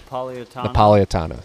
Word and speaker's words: Napolitano: I can't pronounce his name for some reason. I've been trying to do it all day. Napolitano, whatever, a judge. – Napolitano. – Thank you.